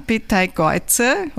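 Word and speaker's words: Beteigeuze: Aber vielleicht gehen wir zuerst auf den Inhalt, weil dein schöner Stern, Petai Geuze, hoffentlich habe ich es jetzt richtig ausgesprochen, perfekt.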